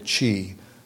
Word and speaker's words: qi: and the ancient Chinese called it qi. Could be spelled Q-I, C-H-I is an older way, and phonetically C-H-E-E.